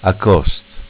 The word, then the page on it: Acost: dialetto